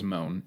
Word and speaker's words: Mohn: Jack and I's last name is Moon, M-O-H-N. – Supposed to be pronounced Moon, actually.